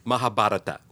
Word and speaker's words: Mahabharata: The way I learned to pronounce it was Mahabharata.